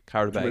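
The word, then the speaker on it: Qarabağ: So the first team we got drawn up against is a hard one to pronounce, but it's Qarabag from Azerbaijan. Qarabag.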